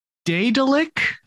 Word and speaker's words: Daedalic: So, Daedalic, I believe it's pronounced.